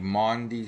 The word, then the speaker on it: Maundy: So my best advice is Google search or Bing search, whatever, the Maundy Thursday story. Maundy is spelled M-A-U-N-D-Y. I am not mispronouncing the word Monday.